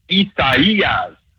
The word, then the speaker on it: Isaias: It's four syllables. The third one, you got to kind of draw that fourth syllable down like Louisville. It's Isaias. That's how they want us to say.